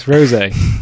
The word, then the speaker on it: rosé: It's rosé, the wine. – Yeah, I missed the little thing above the E.